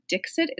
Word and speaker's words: Dixit: One that we play with Jaya is called Dixit. It's spelled D-I-X-I-T.